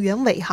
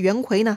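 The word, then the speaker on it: 袁隗: 这儿插播一下袁绍的叔叔袁伟哈，袁伟的伟呢是槐树的槐去掉木字边改成双耳旁，哎，这个字呢还是个多音字，一读伟或者呢读魁。所以啊，到底这个人读成袁伟还是袁魁呢，还是有争议的。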